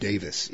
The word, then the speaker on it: Davies: Actually, I think his name is pronounced Davis, even though it's IES.